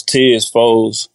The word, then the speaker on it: Tezz 4oez: I pronounce Tez Foes, T-E-Z-Z, the number 4, O-E-Z. – Say it one more time for him. – T-E-Z-Z, 4, O-E-Z.